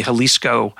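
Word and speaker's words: Xalisco: And um, it's spelled with an X, X A L I S C O, the Jalisco.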